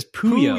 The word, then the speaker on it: Puyo: The way you're pronouncing the game is really what Kirby tends to say as a language. – Oh. – The game is Puyo. – Puyo. I was saying Puyo Puyo.